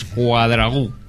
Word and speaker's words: Cuadragu: Es que no sé por qué siempre pienso que es esdrújula y digo Cuadragu. No, es Cuadragu. – Ahí con acento. – Cuadragu, exactamente, con acento en la U. – Bien marcado. En la U última.